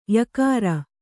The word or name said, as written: ಯಕಾರ